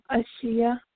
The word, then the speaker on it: Ashia: Ashia. – Ashia, can you spell that for me? – Yes, ma'am. A-S-H-I-A.